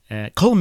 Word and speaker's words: Colm: Okay, so I've heard, I always thought it was Colm, because that's how you read it, right? And then I've heard people say Colm.